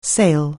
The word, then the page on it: sale: anglais